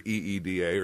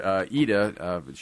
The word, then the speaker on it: Ida: Because that's how they pronounce it in Ida. – The film. It's spelled IDA. So if you're looking for EEDA or something like that, you're not going to find it.